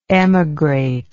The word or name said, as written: emigrative